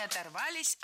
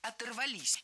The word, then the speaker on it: оторвались: Не оторвались, а оторвались. И здесь все немного проще. Дело в том, что возвратная часть «ся» или «с» в большинстве случаев притягивает к себе ударение. Вот и в нашем примере «оторвались» ставим ударение на окончание, которое вплотную подобралось к этому самому «с».